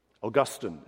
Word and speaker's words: Augustine: Augustine is how you pronounce it in English.